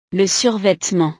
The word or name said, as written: le survetement